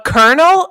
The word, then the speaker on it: colonel: But Colonel? I'm like, how do you get Colonel? – Thank you. How do you? – There's no R.